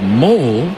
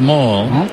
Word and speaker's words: Moll: Sam Moll, M-O-L-L. Some people have pronounced his last name as Mole, but it is Mall. – Okay. – As in shopping.